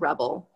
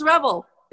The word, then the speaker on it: Rebel: So, um, Dr. Baker um, uh, get my name wrong, and she calls me Reba instead of Rebel, and I let her do that because she basically. and um, but my name is Rebel, it's Rebel, not Reba, guys.